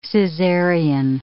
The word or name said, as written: Caesarean